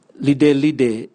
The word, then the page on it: lidelide: Raga